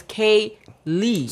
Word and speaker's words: Kayla: They call me Keely, Kylie, R. Kelly, and it's like, no, it's Kay Lee.